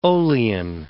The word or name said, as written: olein